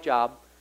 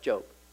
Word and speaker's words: Job: Go read the book of Job. Spelled like Job, pronounced Job. Here's a guy.